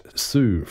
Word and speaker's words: Hsu: Su from Brisbane. Su is spelled H-S-U, and he's also known as the Matt Su's Obscure Orchestra, M-H-O-O.